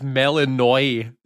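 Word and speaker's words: Melinoë: It's Melanoi is how Melanoi, you pronounce it.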